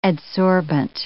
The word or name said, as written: adsorbent